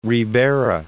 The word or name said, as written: Ribera